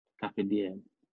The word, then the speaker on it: Carpe Diem: Carpe Diem.